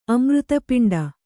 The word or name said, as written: ಅಮೃತಪಿಂಡ